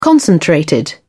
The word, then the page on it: concentrated: British English